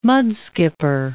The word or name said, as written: mudskipper